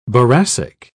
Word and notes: boracic: British English

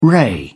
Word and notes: ray: British English

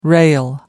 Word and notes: rail: American English

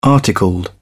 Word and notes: articled: British English

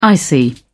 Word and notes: icy: British English

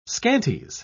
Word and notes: scanties: British English

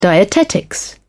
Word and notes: dietetics: British English

